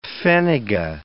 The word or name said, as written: pfennige